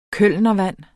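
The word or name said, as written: KØLNERVAND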